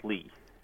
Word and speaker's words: Lih: It's spelled L-I-H. – And it's pronounced Lee, right?